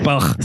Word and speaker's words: Baugh: That's how it's spelled, bro. Yeah. B-A-U-G-H. Bach.